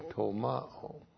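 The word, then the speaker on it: tolmao (τολμάω): So logizomai is followed by an infinitive and it's followed by the verb tolmao. T-O-L-M-A long O.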